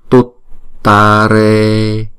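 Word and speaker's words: tuttāre: Tare, chữ này là tut tare, chữ này có hai chữ t thì chúng ta sẽ đọc là tut tare.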